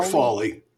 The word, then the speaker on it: Fawley: Sure, Fawley.